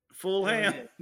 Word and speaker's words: Fulham: Fulham. That's the correct pronunciation.